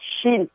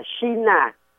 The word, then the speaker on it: China: là C-H-I-N. Đúng lý đó, mình phải đọc là xin mới đúng. Nhưng người Pháp đó thì họ gọi, người Trung Hoa họ gọi là Chinois. Đó, Chinois. Và xứ Trung Quốc họ đọc là xin, sờ. Đó, họ đọc là phần sờ, cong lưỡi lên là phần sờ. Nhưng mà khi qua bên tới tiếng Anh mình đó, thì cái chữ CH đó là người Anh, người Mỹ họ đọc lầm. Họ đọc lầm thế là chờ, giống như chữ church. Church là nhà thờ đó, C-H-U-R-C-H, church.